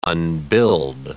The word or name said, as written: unbuild